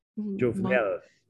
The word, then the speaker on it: Jovenel: So speaking still on the topic of presidents, I may need your help pronouncing this one as well. the former president, Jovenel. – Jovenel. – Jovenel Moise? – Moise. – Moise, yes. Okay.